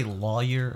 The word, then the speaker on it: lawyer: I have this notion that, you know, how do you say lawyer or lawyer? – Lawyer. – Lawyer, like L-O-I, your? – Lawyer. Lawyer. – I don't like say lawyer. – Like loiter. Lawyer. Loiter without the T.